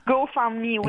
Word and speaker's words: GoFundMe: GoFundMe, exactement.